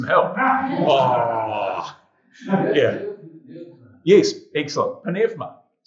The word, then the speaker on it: pneuma: So they pronounce both the P and the N, pneuma. But a U is a V. So it's pneuma. So like the word eureka is actually evrika, not eureka. So just a little one there. If you're talking to a Greek, you've got to pronounce it pneuma, not pneuma.